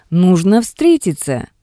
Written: Nugno vstretitsya

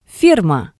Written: ferma

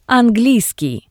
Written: Angliiskii

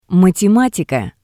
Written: matematika

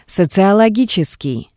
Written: Sociologitseskii

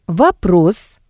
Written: Vopros

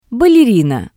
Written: ballerina

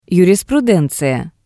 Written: yurisprudenziya

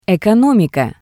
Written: ekonomika

